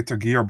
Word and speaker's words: Tagir: So I always say Tagir, but I don't know. I'm just saying how I say it, but I don't know. – No, I think you're right. I think I've been saying it wrong the whole time.